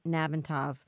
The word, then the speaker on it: Nalbantov: Lisa, how do you say your last name? – It's Lisa Nalbantov. If you break it up in three syllables, it's not so bad.